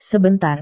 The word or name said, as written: sebentar